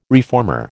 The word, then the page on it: reformer: American English